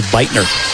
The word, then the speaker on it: Buettner: Beitner spells her name B-U-E-T-T-N-E-R, but pronounces it Beitner.